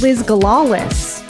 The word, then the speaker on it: Liz Galalis: My handle is my name, at Liz Galalis. And that is spelled LizGalalis.